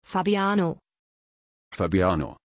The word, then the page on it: fabiano: German